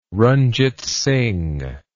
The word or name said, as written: ranjit singh